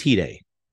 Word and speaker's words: Hide: I kept calling him Hade, and his name is Hide.